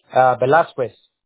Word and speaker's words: Velasquez: Velasquez.